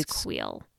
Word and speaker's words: Kuiil: I had someone from Lucasfilm clarify the Ugnaught's name. I believe it's Quill. – Yeah, it's like K-U-I-I-L? – Yeah. – Quill? – It's Quill. – I said Cooley, which is totally wrong.